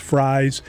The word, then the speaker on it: Fries: I think it's spelled like F-R-E-I-S, but it's pronounced Fries.